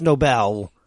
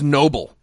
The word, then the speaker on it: Nobel: Okay, first of all, it's pronounced Nobel. – Are you sure? – I heard it was Noble.